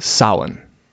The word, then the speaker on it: Samhain: but it's actually pronounced Samhain.